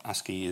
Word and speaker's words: ASCII: By the way, ASCII, as it is now called, ASCII is the way you pronounce A-S-C-I-I, it is not very popular now.